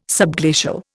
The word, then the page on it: subglacial: Indian